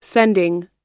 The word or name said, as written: sending